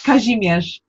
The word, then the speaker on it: Kazimierz: Because her brother was Kamerez Leski. Did I get that right? No, I didn't. I got the G in the middle. tell me how to say it. – Kazimierz. – Yes, that.